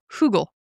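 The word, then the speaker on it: Högel: Hugel. Like Google with an H is how it's actually pronounced.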